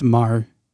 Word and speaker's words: Maher: It's M A H E R. – Yeah, that's Mar.